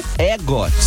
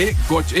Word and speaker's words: EGOT: É EGOT, E-G-O-T. E-G-O-T que fala? EGOT ou EGOT? Eu falo EGOT. Ou, ou então na EGOT. – EGOT, é boa é, também. Que é o E, o, a pronúncia do E em britânico.